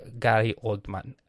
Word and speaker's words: Gary Oldman: Gary Oldman, I got it.